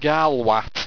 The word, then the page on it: garlwat: Owstwecian